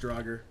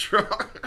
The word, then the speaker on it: Draugr: It's pronounced Draugr. – Pronounced Draugr. – Because every time I see one, I'm like, oh shit, Dreadnought.